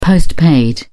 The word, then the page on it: post-paid: British English